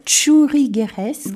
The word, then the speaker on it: churrigueresque: Et quand on parle alors d'un autre style, le style churrigueresque. – Bravo, bien prononcé.